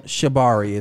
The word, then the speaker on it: Shibari: So it is called Shabari. It's spelled Shibari.